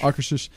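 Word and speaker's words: Akershus: Akershish. – How do you pronounce it? Thanks. – I think that's the proper.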